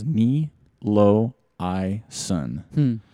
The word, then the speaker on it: Niloayson: And that Twitter, or excuse me, the Instagram handle was Knee Low I Sun. That's how you pronounce it. Knee Low I Sun. NILOAYSON.